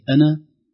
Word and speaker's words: أنا: وهذا يدل على أن كلمة أنا تنطق إذا وقفنا عليها، أم أنا تنطق الألف بمد طبيعي، أما إذا وصلنا الكلمة التي بعدها أم أنا خير من هذا، أنا خير من، إذن هذه العلامة الصفر المستطيل فوق الحرف يدل على نطقه عند الوقف عليه وحذفه عند الوصل بما بعده.